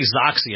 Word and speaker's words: Xoxy: I'm going to say Xoxy, and I'm hoping I'm saying it right, but it's XOXY. And a lot of it has to do with online dating.